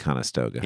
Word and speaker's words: Conestoga: A Conestoga? Conestoga? Conestoga? – I think you got it right the first time. Conestoga.